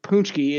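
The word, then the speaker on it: pączki: And in Polish circles, the the treat is a punchki. It's it's spelled P A Z P A Z.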